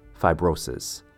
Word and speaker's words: fibrosis: Next up, we have fibrosis. Three syllables. Phi has a long I sound, phi. Bro sounds like what you'd call a good guy friend. Hey, bro. Sis is exactly how it sounds. Sis, like a brother and sis. Fibrosis.